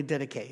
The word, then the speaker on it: Didache: It's you'll hear it's pronounced several different ways. A Greek guy, uh, um, professor told me Didache.